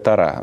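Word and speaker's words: Тора: Принято так ударение. – Да? Ну, хорошо. – У нас. Я знаю, что в иудаизме в некоторых традициях говорят «тара». Да, да, ну, по-еврейски правильно, да.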